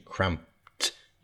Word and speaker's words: cramped: And you got that pronunciation, cramped, like a T sound at the end, cramped. Even though it's spelt C-R-A-M-P-E-D, the E-D ending has got a T sound, cramped.